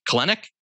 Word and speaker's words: Kelenic: Kelenic, key of the 140 batting average.